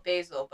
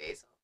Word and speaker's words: Basil: It's spelled Basil, but with an accent, it'd probably be Basil.